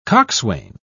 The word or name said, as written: coxswain